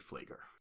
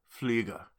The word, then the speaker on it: Flieger: You know, I've heard it pronounced Flieger before, and it made me doubt myself, but it's definitively Flieger. – Flieger.